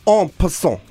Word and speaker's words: en passant: This is en passant. – En passant. – Like French, you know, if we want to get really, really pompous with this.